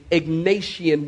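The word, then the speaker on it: Egnatian: Paul and Silas are traveling on a Roman road known as the Ignatian Way. Let me spell it. E-G-N-A-T-I-A-N. E-G-N-A-T-I-A-N. The Ignatian Way.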